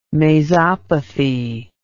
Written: mazopathy

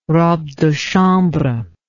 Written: robe de chambre

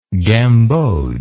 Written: gambogian

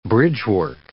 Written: bridgework

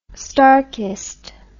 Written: starkist

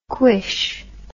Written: cuish

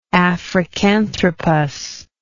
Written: africanthropus

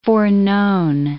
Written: foreknown

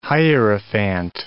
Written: hierophant

